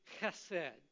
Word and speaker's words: chesed: It's still pronounced chesed. It, the second part of it rhymes with bed. The first part, you find that by finding that in between sound between a K and an H, but you've got to bring it from the back of your throat.